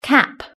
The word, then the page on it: cap: British English